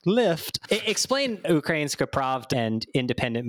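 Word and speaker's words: Ukrainska Pravda (Українська правда): So explain to me, to everyone who's listening, they probably don't know a ton about like Ukrainian media, but explain Ukrainska Pravda. I don't know if I'm pronouncing it correctly. So you're you're pronouncing it like quite professionally.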